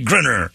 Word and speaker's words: Griner: Yes, another broken backboard from the incredibly light-handed dunk from Brittany Griner. I understand I'm saying it wrong. It's called Griner.